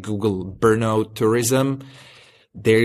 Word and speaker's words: Brno: Brno? How's it pronounced? – Brno. No, it's Brno. It's actually just a four-letter city.